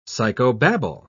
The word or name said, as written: psychobabble